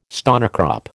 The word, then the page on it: stonecrop: American English